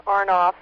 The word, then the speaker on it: Arnoff: Married name is Arnoff, A-R-N-O-F-F as in Frank, Arnoff. First name, Rosalie, R-O-S-A-L-I-E.